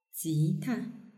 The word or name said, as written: jita